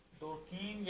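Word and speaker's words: Durkheim: ایمل ڈورکن یا ڈور کہیں دونوں تلفظ ہیں, اب انگلش میں کیسے ہے نہیں دیکھا.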